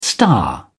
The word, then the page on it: star: British English